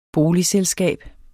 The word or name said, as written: boligselskab